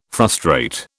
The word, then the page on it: frustrate: British English